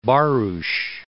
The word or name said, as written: Barros